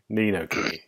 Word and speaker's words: Ni no Kuni: Ni no Kuni, if I said that right? – Ni no Kuni, yeah.